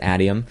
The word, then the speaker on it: Adium: It's called Adium, or I'm not sure how to pronounce it. I pronounce it Adium.